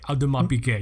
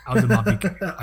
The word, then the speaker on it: Audemars Piguet: Audemars Piguet. You have to say it right. Audemars Piguet.